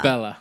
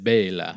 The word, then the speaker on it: Bela: Bella. or how they properly pronounce it, Bela.